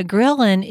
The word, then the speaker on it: ghrelin: You've probably seen the word leptin, a very straightforward word, L-E-P-T-I-N. And then, but ghrelin is such a tough word to pronounce. It's G-H-R-E-L-I-N, but it's pronounced ghrelin, like you're grilling on the barbecue.